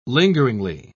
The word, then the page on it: lingeringly: British English